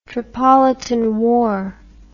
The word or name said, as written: Tripolitan War